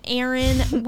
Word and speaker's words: Eryn: My channel is my name, Eryn Weaver. – How do you spell it? – E-R-Y-N, not an I, and it's not A-A-R-O-N.